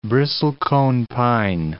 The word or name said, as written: bristlecone pine